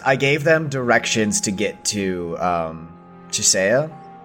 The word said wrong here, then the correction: Chiesa: Am I saying that right? Chisea? And I told them... – Chiesa.